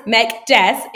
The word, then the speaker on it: Mekdes: It gets mispronounced a lot. Some people call me Mekdes. Just kidding. But no, actually what I get a lot is Mekdes. When I was in college, kids used to say Mekdes and just be silly and funny.